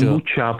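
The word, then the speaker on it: kombucha: Tak vám řekni, jestli vyslovuješ kombuchu, jestli vyslovuješ kombuchu s ch, nebo s č. – Kombuča, pro boha.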